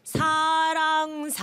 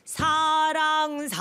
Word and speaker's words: 사랑: And pronunciation 사랑 Ah, oh. yes. – The ing sound.